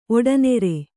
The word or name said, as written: ಒಡನೆರೆ